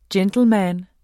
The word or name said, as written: gentleman